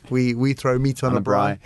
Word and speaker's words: braai: But is it a braai? – A braai, which I got that right.